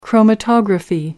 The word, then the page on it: chromatography: American English